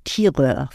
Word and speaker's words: Tireur: Also und äh, die, die Spieler heißen dann Leger und Schießer oder Pointeur und Tireur, französisch ausgesprochen.